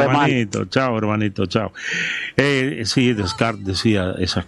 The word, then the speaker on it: Descartes: Descartes. Es un poeta francés. Ajá. Se escribe Descartes. Se pronuncia, sí, Descartes.